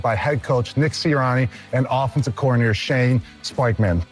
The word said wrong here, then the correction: Steichen: There's no P in Shane Steichen's name.